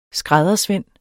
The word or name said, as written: skræddersvend